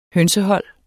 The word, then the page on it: hønsehold: [ ˈhœnsəˌhʌlˀ ]